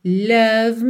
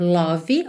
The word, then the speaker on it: love: Won't you say you love me? Atenção para não dizer love, ok? Love, o E final é mudo, love, o som para no V, ok?